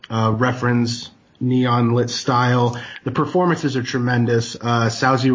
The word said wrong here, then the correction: Saoirse: Rowan, I cannot pronounce her name correctly off the top of my head. – It's Saoirse. – Saoirse.